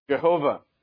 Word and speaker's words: Jehovah: Jehovah. And I believe that is the right pronunciation for that as well.